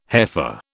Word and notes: heifer: British English

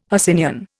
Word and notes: Hercynian: Indian

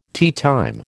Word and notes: teatime: American English

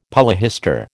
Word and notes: polyhistor: American English

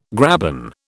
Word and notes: graben: British English

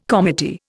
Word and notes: comity: Indian

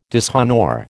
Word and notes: dishonour: American English

